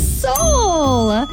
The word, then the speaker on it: Seoul: S-E-O-U-L. – Yes, Seoul. It's pronounced Seoul, South Korea.